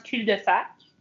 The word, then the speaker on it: cul-de-sac: And by the way, the S silent in French. So in French, it's pronounced cul-de-sac.